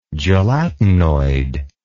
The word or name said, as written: gelatinoid